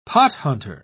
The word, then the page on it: pothunter: British English